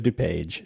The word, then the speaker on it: DuPage: How do you pronounce that?" – College of DuPage.